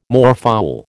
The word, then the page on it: moorfowl: American English